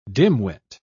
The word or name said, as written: dimwit